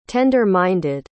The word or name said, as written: tender-minded